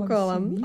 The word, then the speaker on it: McCollum: McCollum.